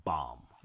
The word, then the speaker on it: Bam: Well, what's like Bomb or Bam? I don't know how you pronounce it. – Bomb. – Bomb. Yes.